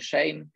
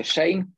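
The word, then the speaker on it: Schoen: ponoć czyta się Shane, pisze się Shen, Shoen, e, ale czyta się Shane, tak przynajmniej twierdzą dziennikarze w Stanach, więc tego się trzymajmy.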